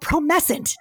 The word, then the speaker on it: Promescent: And the thing he has is called, it's called Promescent. Did I say it right? – You said it exactly right.